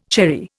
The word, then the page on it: cherry: Indian